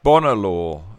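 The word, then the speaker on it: Bonar Law: I think it's pronounced Bonner Law. Bonner Law, Simon.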